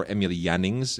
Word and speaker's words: Emil Jannings: Blue Angel, uh, Marlena Dietrich, and of course Emil Jannings, who uh, or Emil Jannings, if you want to do the German pronunciation, who of course was a big deal in American films at the time as well.